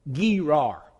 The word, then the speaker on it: Gerar: Gerar. I want to say that another way, but I looked up the pronunciation.